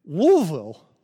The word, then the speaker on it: Louisville: Oh, I'm sorry, not, not in Louisville, in, in Louisville. Louisville. I don't know why you guys say it like you're throwing something up around here, but that's the way they say it. So I'm going to say it like that.